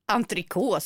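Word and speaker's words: entrecôte: Entrecote, så T uttalas inte. – Jo, det gör det. När det är en tyst vokal, mjuk tyst vokal sist i franska, då uttalar du den sista konsonanten, entrecote.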